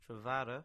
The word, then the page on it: Tredavoe: English